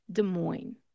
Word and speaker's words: Des Moines: we're going to leave off that S, and instead we're just going to say Des Moines. Des Moines. No S sound at the end.